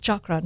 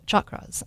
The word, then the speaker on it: chakra: So we'll talk about chakras in a minute, and I always mispronounce it. It's chakra, not chakra.